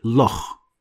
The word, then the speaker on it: loch: And it is always pronounced as Kh too, like the word Loch. Probably you've heard of Loch Ness. So this is actually a Scottish sound, Loch.